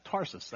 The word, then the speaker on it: Tarsus: Now, you know, once you say it wrong in your head, you can't say it right. Tarshish, whatever. He goes and gets Paul, Saul at the time, and he brings him back. Tarsus, that's it.